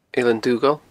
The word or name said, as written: Eilean Dughaill